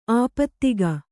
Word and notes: ಆಪಾದಕ: āpādaka

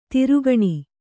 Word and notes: ದೊರೆಗಾಣ್: Kannada

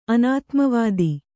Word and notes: ಅನಾತ್ಮವಾದ: anātmavāda

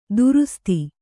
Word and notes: ದುರುಸ್ತಿ: durusti

